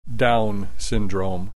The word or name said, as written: Down Syndrome